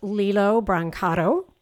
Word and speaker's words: Lillo Brancato: So welcome to the podcast, Lillo Brancato.